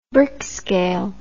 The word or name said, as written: Brix scale